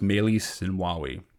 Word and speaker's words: Meles Zenawi: Now, the father of this system was Meles Zenawi, or pronounced Meles Zenawi.